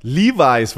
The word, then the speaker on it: Levis: Levi's wird er, wird er ausgesprochen, wird wie die Hose wird er ausgesprochen. Nicht Level.